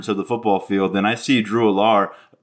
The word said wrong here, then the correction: Allar: there we go. Uh, first mispronunciation. Take a drink. Uh, Drew Drew Allar.